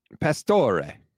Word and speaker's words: Pastore: Pastore. – There you go. Okay. I was making sure you were going to say Ray at the end rather than Re, as most American Italians like to say.